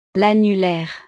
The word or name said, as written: l'annulaire